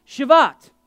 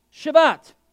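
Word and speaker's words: Shavuot: It was a Jewish festival called Shavat, and I am pronouncing that properly. Shavat, which takes place on the 50th day after Passover and is a celebration of the wheat harvest.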